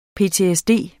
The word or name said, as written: PTSD